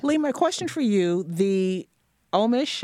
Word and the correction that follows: Ornish: Ornish, thank you.